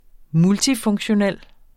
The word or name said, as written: multifunktionel